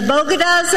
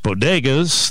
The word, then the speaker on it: bodegas: As distinct as the Bogotas of the Bronx, as beautiful as the blossoms of Miami, and as unique as the breakfast tacos here in San Antonio. – For one thing, she mispronounced bodegas.